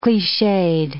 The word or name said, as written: cliched